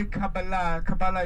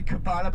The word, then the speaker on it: Kabbalah: By the way, Kabbalah is like an American way to pronounce it. The American Jews say Kabbalah, but actually it's Kabbalah, means reception.